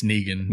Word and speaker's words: Negan: Jeffrey Dean Morgan has joined the cast of The Walking Dead in the role of the villain Negan. I assume I'm saying that right. – It's Negan.